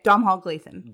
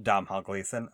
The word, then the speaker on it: Domhnall Gleeson: Okay, Domhnall Gleeson. – Domhnall Gleeson from Ex Machina.